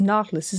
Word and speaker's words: Nautilus: Madsen would go on in his career to build three submarines, the UCI Freya, the UC-2 Kraka, and the UC-3 Nautilus. I was trying to remember how to pronounce that. It's Nautilus, isn't it? – Nautilus.